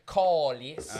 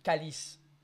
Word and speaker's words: calice: In Quebecois, you would say colice. Uh-huh. But in French, it would be calice.